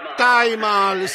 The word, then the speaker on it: Taj Mahal: Come tu, come lo pronunci quello? Hotel Taj Mahal? Taj Mahal, sì, comunque.